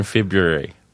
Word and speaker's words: February: February. – You don't say the first R.